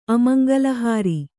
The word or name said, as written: ಅಮಂಗಲಹಾರಿ